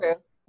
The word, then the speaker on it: Hoult: Holt, like Holt Renfrew.